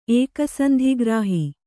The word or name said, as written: ಏಕಸಂಧಿಗ್ರಾಹಿ